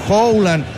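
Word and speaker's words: Haaland: Él quería, claro, dice esto en Noruega, tienen que decir Howland en la pronunciación, pero bien, Marcelito, está bien.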